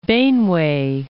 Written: Benue

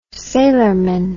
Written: sailorman

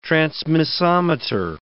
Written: transmissometer

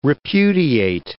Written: repudiate

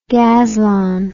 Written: gazlon

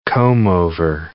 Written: comb-over

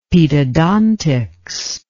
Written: pedodontics

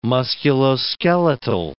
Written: musculoskeletal

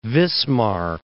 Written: Wismar

